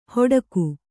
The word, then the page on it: ಹೊಡಕು: hoḍaku